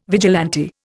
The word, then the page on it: vigilante: Indian